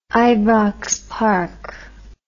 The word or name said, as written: ibrox park